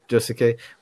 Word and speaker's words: Josuke: Did I say his name right? Sorry if I butchered it. – It's close. Josuke.